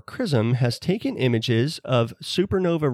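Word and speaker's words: XRISM: It's X-R-I-S-M, but it's pronounced CRISM.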